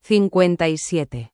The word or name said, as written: 57